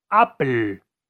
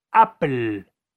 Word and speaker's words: Apple: Bueno, esa es la forma correcta de pronunciarlo en inglés. Apple.